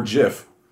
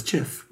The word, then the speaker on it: GIF: Despite what Danny Carpenter says, it is GIF. – It is the most natural, logical way to pronounce it. That's why everyone comes across the word the first time, they use a hard G.